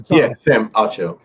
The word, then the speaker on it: Acho: Sam, is it Acho or Ocho? – Sam Acho.